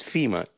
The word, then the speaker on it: Fima: My name is FEMA, just like the organization, but spelled with an I.